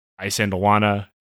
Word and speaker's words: Isandlwana: the Battle of Isandlwana, Isandlwana, whichever. I've heard it pronounced both ways. I believe Isandlwana is correct, and Rorke's Drift.